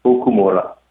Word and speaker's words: Okumura: The last name is pronounced Okumura. – Okumura. I had the accent on the wrong place.